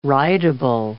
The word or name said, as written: writable